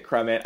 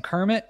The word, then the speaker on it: Crummet: I pronounce it Crummet. I think Crummet. People okay. pronounce it a lot of different ways, so I'm not too particular. All right. I've always said Crummet, and when I first saw it, I kept saying Kermit because I didn't notice the U and the R were different.